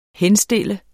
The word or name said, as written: henstille